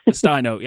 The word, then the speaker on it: Restaino: Did I say that right, Restaino? – Restaino. – Yeah, I was so close.